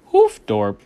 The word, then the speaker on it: Hoofddorp: Ended up in a city that we were staying in called, and shout out to Hoofddorp. That's how you pronounce it. That's how we pronounce that. Apparently not the greatest area. We ended up telling somebody that we were saying Hoofddorp, and they're like, oh, fuck, really?